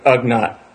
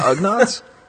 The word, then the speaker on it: Ugnaught: But I just love Attack of the Whatnots, which rhymes with Ugnaught.